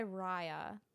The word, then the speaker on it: Riah: Yeah, so I go by Riah, just R-I-A-H, but my actual name is Mariah, M-O.